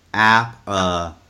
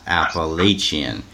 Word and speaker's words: Appalachian: Sam Callen, Bruce, the correct pronunciation of Appalachian State is Appalachian, not Appalachian.